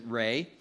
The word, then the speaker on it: Ra: The darkness is also an attack on the sun god Ra. Sometimes they pronounce it Re.